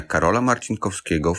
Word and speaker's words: Karola Marcinkowskiego: In City Poznań, there is Uniwersytet Medyczny imienia Karola Marcinkowskiego w Poznaniu, where Harald Wallach was working.